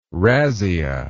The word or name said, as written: razzia